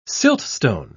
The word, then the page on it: siltstone: British English